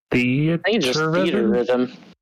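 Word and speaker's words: Theatrhythm: The next one is, how do you say this? Theat Rhythm? – Theater Rhythm. – Theater Rhythm? – Yeah.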